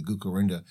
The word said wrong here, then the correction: Gukurahundi: Yeah, Gukurahundi.